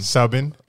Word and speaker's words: Subban: Subin. – Hmm. – Black dude. – Hold on. – Subin. Subin. S-U-B-B-A-N.